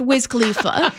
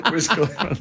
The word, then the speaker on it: Wiz Khalifa: But I do know it's pronounced Wiz Khalifa. – Wiz Khalifa.